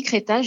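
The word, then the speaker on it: Crettaz: Alors, on dit Créta, j'ai appris ça récemment, pardon. – On ne prononce pas le Z.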